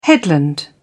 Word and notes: headland: British English

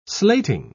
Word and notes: slating: British English

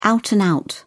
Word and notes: out-and-out: British English

